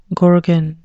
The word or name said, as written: gorgon